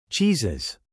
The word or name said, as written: cheeses